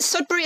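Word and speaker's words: Sudbery: Sudbury is spelled the same way as surgery, so that helps.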